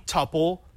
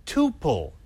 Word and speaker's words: tuple: You can say tuple, you can say tuple. It doesn't matter. Both are accepted.